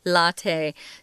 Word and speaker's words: latte: Uh, remember, you probably look at this word and go, why are they saying latte? It looks like lat in English. Uh, mm. again, it's an Italian word, so we're pronouncing it the way they would latte.